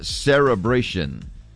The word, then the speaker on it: cerebration: Cerebration. – Cerebration. – Yeah, now you said it correctly. Cerebration. – Never heard of it. – It's not an L. It's not a celebration. Cerebration.